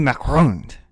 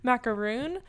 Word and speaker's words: macaron: If all you guys don't know at home, it's not pronounced macaroon, as maybe some people would say it. It's a macaron, yeah.